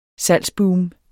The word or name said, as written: salgsboom